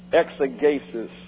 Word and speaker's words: exegesis: Exegesis comes from the Greek word exegesis. Already screwed up. That's E-X-E, long E, G-E, long E, S-I-S. And the G in Greek is always hard.